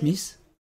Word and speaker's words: Mises: alors, il y en a un, je ne sais pas comment ça se prononce, c'est Ludwig von Mises, Mises oui.